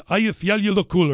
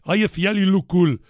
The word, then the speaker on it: Eyjafjallajökull: It's Eyjafjallajökull or something like that. I can't remember. Eyjafjallajökull.